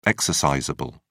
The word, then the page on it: exercisable: British English